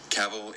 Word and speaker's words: Cavill: Cavill is pronounced like travel. Travel. Cavill.